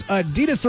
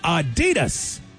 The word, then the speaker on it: Adidas: Random fact number four, Americans pronounce Adidas wrong. It's supposed to be Adidas, but we say Adidas.